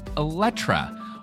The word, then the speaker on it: Eletre: So, to correct myself, it's actually pronounced Eletre, not Eletre or anything else.